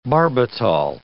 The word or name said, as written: barbital